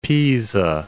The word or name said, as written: Pisa